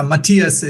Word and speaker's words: Mathias: Mathias or Mathias. I tell them it's freaking Mathias, and I'll punch them if they say anything else.